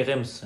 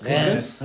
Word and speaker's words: Reims: Spricht man das richtig aus? – Sadrem. – Bei Rems. Nee, Rems. – Nenn ich Rems. – Rems.